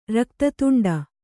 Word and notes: ರಕ್ತತುಂಡ: Kannada

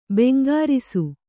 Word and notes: ಭುಂಕರಿಸು: Kannada

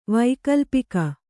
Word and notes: ವೈಕಲ್ಪಿಕ: kannada

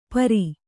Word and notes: ಪೈರೆ: paire

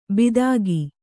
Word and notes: ಬಿದಾಗಿ: bidāgi

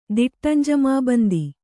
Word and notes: ದಿಟ್ಟಂಜಮಾಬಂದಿ: diṭṭam jamābandi

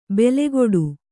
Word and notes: ಬೆಲೆಕೊಡು: bele koḍu

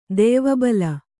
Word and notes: ದೆಯ್ವಬಲ: Kannada

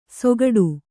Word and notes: ಸೊಗಡು: sogaḍu

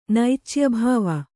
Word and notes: ನೈಚ್ಯಭಾವ: naicya bhāva

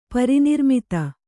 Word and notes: ಪರಿನಿರ್ಮಿತ: Kannada

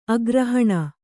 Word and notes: ಅಗ್ರಹಣ: kannada

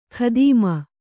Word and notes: ಖದೀಮ: khadīma; Kannada

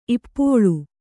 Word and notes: ಇಪ್ಪೋಳ್: Kannada

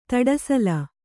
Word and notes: ಧೂಢಸೆಲ: kannada

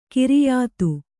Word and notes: ಖೈರಿಯತ್ತು: khairiyattu

